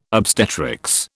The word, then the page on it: obstetrics: British English